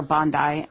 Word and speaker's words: Bondi: The first two are beaches, and I say Bondi Beach. – That's right. How I've heard it, yeah. – Or Bondi.